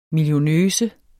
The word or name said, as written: millionøse